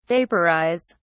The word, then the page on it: vaporise: British English